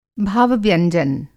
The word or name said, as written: भाव-व्यंजन